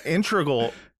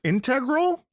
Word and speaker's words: integral: Integral. – How do you pronounce it? – Integral. There you go. – Yeah. All right. – Or integral. You can say it either of those two ways, I think.